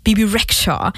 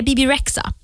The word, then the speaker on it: Bebe Rexha: Lagt H-et på fel ställe och därför vill jag uttala det Bebe Rexha. Men det är Bebe Rexha.